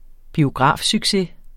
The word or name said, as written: biografsucces